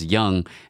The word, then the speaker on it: Duong: Like, I know your last name is pronounced Young.